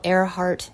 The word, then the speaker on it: Earhart: I almost named my son after Amelia Earhart. I admire how Earhart is pronounced like the sky she flew in and not the way it looks, like the organ of hearing.